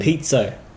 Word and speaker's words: piezo: Pizzo, like pizza with an O on the end.